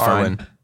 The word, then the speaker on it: Arwen: Awen. – But Arwen. It's Arwen. Fine.